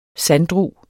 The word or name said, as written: sanddru